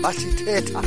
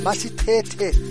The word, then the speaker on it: Masi Teta: She said to me, your pronunciation. Masi Teta. Masi Tete, as Jeline could say.